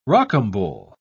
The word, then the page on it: rocambole: British English